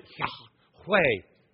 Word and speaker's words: Yahweh: He describes up His name as Yahweh. Now I say Yahweh because there are no vowels in the Hebrew language, and so they would pronounce it Yahweh.